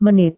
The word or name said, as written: menit